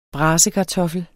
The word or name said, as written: brasekartoffel